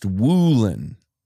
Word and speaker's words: Woolen: I'm sorry, Reek Woolen, apparently, according to Al Michaels. He wants it to be pronounced Woolen.